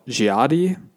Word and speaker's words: Dziady: And then the the Halloween type tradition or festival is called Dziady.